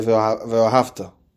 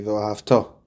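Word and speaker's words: Ve'ahavto (וְאָהַבְתָּ): A very common example of that is when we say Shema, so we say Ve'ahavta. The proper way to say that would be Ve'ahavto.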